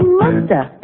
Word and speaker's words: must have: We can say must have in conversation. And sometimes we don't pronounce the Z. And so you hear musta.